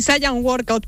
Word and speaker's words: Saiyan Workout: Sí, Saiyan Workout, pero lo puedes pronunciar como tú quieras. Lo podemos españolizar un poquito si quieres.